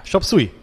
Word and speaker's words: Chop Suey: Heißt das, nennt man das ja. – Chop Suey? – Ja, Chop Suey.